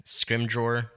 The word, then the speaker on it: Scrimgeour: How did you say it? Scrimgeour? – Scrimgeour. That's how I've always read it.